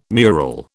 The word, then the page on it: mural: British English